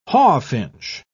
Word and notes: hawfinch: British English

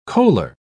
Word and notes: coaler: British English

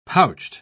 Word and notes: pouched: British English

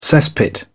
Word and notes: cesspit: British English

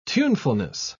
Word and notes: tunefulness: British English

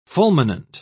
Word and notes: fulminant: British English

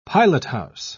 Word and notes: pilothouse: British English

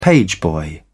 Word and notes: pageboy: British English

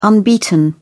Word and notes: unbeaten: British English

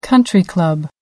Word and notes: country club: American English

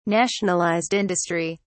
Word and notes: Nationalized industry: British English

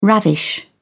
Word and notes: ravish: British English

